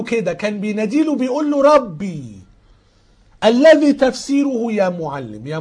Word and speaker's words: Rabbi: دي كلمة عبرية، كلمة عبرية نطقها كده ربي، R A B B I أو رو ألفا بيتا بيتا إيوتا.